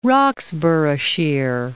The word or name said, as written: Roxburghshire